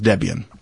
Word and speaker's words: Debian: Is it Debian? Yeah, it's Debian.